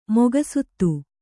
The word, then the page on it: ಮುಖಸುತ್ತು: mukha suttu